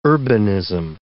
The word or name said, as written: urbanism